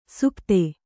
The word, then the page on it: ಸುಪ್ತೆ: supte